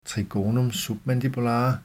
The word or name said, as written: trigonum submandibulare